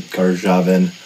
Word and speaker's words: Karjavine: Karjavine. – That's how I pronounce it. My mother pronounced it Karjavine.